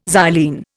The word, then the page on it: xylene: Indian